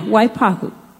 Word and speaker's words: Waipahu: Warren Toyama from how do you say that? Waiapahu, right? Hawaii? Do that again, Terry Lynn. Waihapi? Waipapu. Okay. I'm so sorry. These are, yeah, Waipahu. I got it.